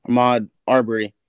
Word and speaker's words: Ahmaud Arbery: Ahmaud Arbery.